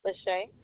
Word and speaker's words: Lashaye: Lachey. – Can you spell that for me? – L-A-S-H-A-Y-E.